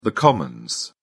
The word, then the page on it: commons: British English